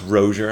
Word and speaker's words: Rozier: Pronounced Rozier. I'm not that French.